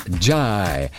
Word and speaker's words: Njie: Tijan Jai. Keiner würde vermuten, dass er so ausgesprochen wird, denn der Nachname wird geschrieben N-J-I-E.